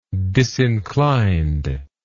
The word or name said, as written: disinclined